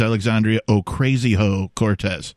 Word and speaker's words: Alexandria Ocasio-Cortez: I have to correct you. It's Alexandria Ocasio-Cortez. I have it on good information. That's how the pronunciation is.